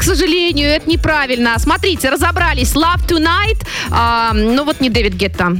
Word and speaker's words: Love Tonight: Да, «Love Toning». Ну, если правильно произношу. – Ну, почти правильно. «Love Tonight» называется она.